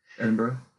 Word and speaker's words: Edinburgh: Edinburgh.